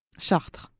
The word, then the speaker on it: Chartres: How say Charter Street? – Chartres.